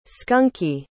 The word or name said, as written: skunky